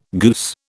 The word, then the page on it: goose: British English